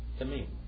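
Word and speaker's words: Tamim (תמים): Tamim.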